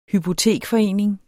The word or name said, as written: hypotekforening